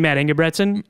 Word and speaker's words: Matt Ingebretson: Did you mean Matt Ingebretson? – Yes.